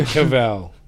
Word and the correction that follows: Cavill: Henry Cavill.